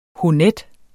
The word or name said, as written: honnet